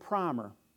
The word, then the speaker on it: primer: And the first part of the book is called the New England Primer. They say primer. It's spelt primer.